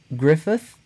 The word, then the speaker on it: Gruffudd: His name looks like it's spelled Ion Gruffudd. – Okay. – But it's actually Jan Griffith. I don't know how they get the TH in there. It is Gruffudd. – And it's Griffith.